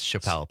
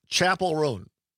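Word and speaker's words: Chappell Roan: I would have also guessed Chappelle based on how it's spelled.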